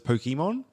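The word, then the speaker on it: Pokémon: Yeah, because we say Pokemon, like Pokemon in Western culture, but it's Pokemon.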